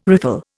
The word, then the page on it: riffle: Indian